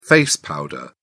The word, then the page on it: face powder: British English